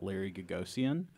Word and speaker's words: Gagosian: I recently read in The New Yorker an article about Larry Gagosian. – Mm-hmm. – Am I pronouncing his name right? – Yes.